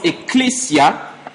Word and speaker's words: ecclesia: Le mot mystère vient d'un mot, on l'a dit la semaine dernière, un mot grec qui se prononce mysterion, qui veut simplement dire secret. C'est pour ça que dans la version française courante ou dans la version semeur, il est écrit, il vous a été donné à vous de connaître les secrets du royaume. Et nous avons dit que l'église vient d'un mot grec que l'on prononce ecclesia